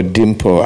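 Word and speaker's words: Limpho: Aber Dimpo Hani, es ist geschrieben Limpo, aber Dimpo ausgesprochen, sein Status geht über die Ehe mit Chris Hani und machen sie große Beschwer gegen die Freilassung von die Mörder Wallace.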